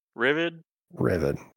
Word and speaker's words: Rivod: Either way, how do you say it? – Rivad. – Okay. – like Rivet, I guess.